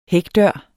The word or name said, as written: hækdør